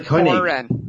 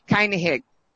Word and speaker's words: Koenig: Walter Koenig. – Koenig.